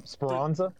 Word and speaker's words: Speranza: Speranza? I believe that's how it's pronounced.